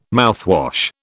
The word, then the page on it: mouthwash: British English